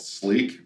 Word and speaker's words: Sliq: So the second product that we came out with was a product called Sleek, which is spelled S-L-I-Q.